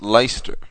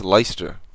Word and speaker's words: Leicester: So, believe it or not, that's actually pronounced Leicester. – Is it really? – Yeah, that's Leicester.